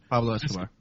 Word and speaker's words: Pablo Escobar: Pascobar. – Right. – Pablo Escobar.